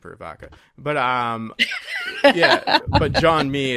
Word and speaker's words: Jean-Mi: Jean-Mi. – Is it Jean-Mi? I thought it was Jean-Mi. Oh, okay. Jean, like J-E-A-N, the French pronunciation, and then Mi, M-I. Jean-Mi.